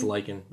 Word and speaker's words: Lykken: Yeah, it's, lichen? it's lichen, but it's not spelled like you know the no.